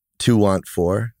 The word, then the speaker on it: TWNT4: You began your fashion journey with the streetwear brand To Want For. Is that pronounced correctly? – Yeah, it's an abbreviation of 24, but not the tightest abbreviation. Yeah. It's TWNT4, but I've heard everything from Twanta.